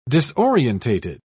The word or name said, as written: disorientated